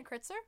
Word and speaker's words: Kritzer: Leslie Kieser? Kaiser? Huh? – Isn't it Kritzer? – It might be. – Leslie Kritzer from Beetlejuice? – Yeah.